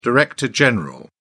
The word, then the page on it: director general: British English